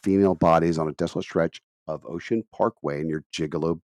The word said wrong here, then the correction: Gilgo Beach: or I call it called Gigolo Beach. It's Gilgo Beach, by the way. Just a gigolo. Sorry, Gilgo Beach. I don't know why I keep mispronouncing that.